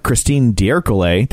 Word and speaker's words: Christine D'Ercole: There's a Christine D'Ercole.